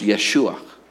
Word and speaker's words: Yeshua: Joshua in Hebrew comes from two words, Yahweh and Shuah. Shortened and combined together in the name Joshua, it's pronounced Yeshua.